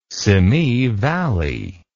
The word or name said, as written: Simi Valley